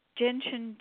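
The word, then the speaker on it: Jin Shin: Jinshin Jitsu.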